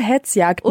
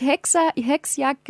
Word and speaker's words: Hetzjagd: Und Hetzjagden. – Und nicht Hexjagd gesagt, sondern Hetzjagd.